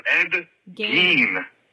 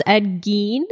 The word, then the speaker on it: Ed Gein: His name is Ed Gein. – Oh, Gein. – I could never finish that episode. – Oh, sorry. I'm so sorry. I'm so sorry. – Bye. – It's pronounced Ed Gein.